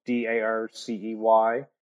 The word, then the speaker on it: Darcey Lynch: So it's Darcy Lynch, and my name is spelled with an E. So it's Darcey Lynch.